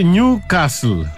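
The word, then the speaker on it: Νιουκάστλ: Προφέρεται Νιου Κάστλ. – Δεν έχει τάφ, έχει δίκιο. – Ναι, έχει δίκιο. Νιου Κάστλ. Άνευ του.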